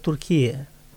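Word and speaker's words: Türkiye: Турция, вот само по названию, Турция – это русское произношение. А турки-то произносят Туркия.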